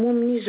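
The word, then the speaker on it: Moumni: Madame Moumi Zulika. – Comment s'écrit le nom de famille? – Écrit M-O-U-M-N-I. Z-O-U-L-I-K-H-A.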